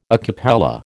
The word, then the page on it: a cappella: American English